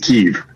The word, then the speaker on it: Kyiv: it is not as easy as simply saying Kyiv. It's not a single-syllable city. It is Kyiv.